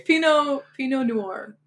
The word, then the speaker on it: Pinot Noir: they kept talking Pinoy Noir. Is that how you say it? – Sure. I don't know either. – Oh, okay. – Pinot Noir.